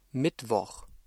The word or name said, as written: Mittwoch